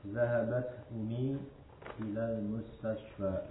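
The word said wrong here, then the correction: ummi (أمي): Hanya pengucapan ummi itu ada tasdid sebenarnya di situ.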